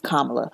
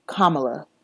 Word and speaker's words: Kamala: So, first things first, Miss Kamala. First of all, I just want to start there. Her name is Kamala. Kamala.